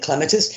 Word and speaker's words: clematis: No, he was right calling it clematis. It's properly pronounced clematis from the Greek word klema, to climb, because clematis is a climber.